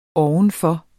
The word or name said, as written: ovenfor